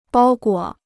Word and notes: 包裹: bāo guǒ

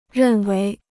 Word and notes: 认为: rèn wéi

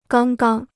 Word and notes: 刚刚: gāng gang

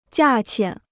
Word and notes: 价钱: jià qian

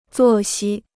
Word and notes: 作息: zuò xī

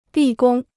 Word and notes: 立功: lì gōng

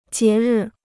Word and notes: 节日: jié rì

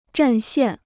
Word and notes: 占线: zhàn xiàn

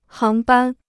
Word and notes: 航班: háng bān

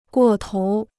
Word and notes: 过头: guò tóu

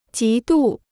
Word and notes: 极度: jí dù

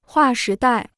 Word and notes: 划时代: huà shí dài

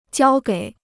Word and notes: 交给: jiāo gěi

